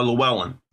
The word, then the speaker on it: Llewellyn: How do you pronounce it, Llewellyn? Uh, Llewellyn.